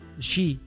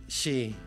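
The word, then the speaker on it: sidhe: Shi. – Shi, que se escribe completamente diferente a lo como lo estás pronunciando, pero se escribe side. Okay. Pero se pronuncia shi. – Shi. – O sea, se escribe S-I-D-D-H-E.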